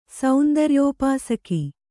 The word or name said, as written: ಸೌಂದರ್ಯೋಪಾಸಕ